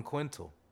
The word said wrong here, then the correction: quintile: I don't think that word's quintal. – Quintile. – Quintile? Percentile? Is there a P? – With a T at the end. – Quintile. That's how you say it.